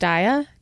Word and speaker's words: Daiya: I think it's pronounced Daiya, D-A-I-Y-A.